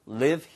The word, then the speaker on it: live: 자, 예전에는 여기서 살았었는데 we used to live here. 그렇죠? We used to live here. 길게 발음하시면 떠나다는 뜻이니까 L-E-A-V-E, leave고요. 얘는 live, live 이렇게 발음하시는 게 좋습니다.